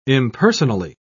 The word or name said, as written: impersonally